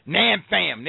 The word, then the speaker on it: Nam Pham: I wish I had pronounced his name right, because Nam Pham.